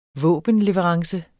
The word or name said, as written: våbenleverance